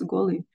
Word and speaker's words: Kolu: It's spelled as Kolu but pronounced as Golu.